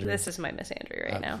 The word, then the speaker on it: Missandei: if you've seen Game of Thrones, is it Missandei? How do you say her name? – Missandei.